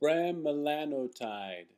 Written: Bremelanotide

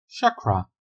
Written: Chakra

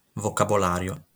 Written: vocabolario

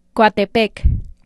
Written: Coatepec

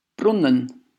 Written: Brunnen

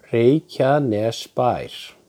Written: Reykjanesbær

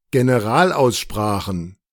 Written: Generalaussprachen

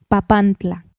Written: Papantla

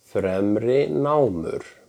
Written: Fremrinámur

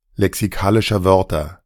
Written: lexikalischer Wörter

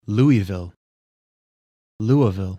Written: Louisville

